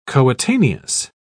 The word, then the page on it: coetaneous: British English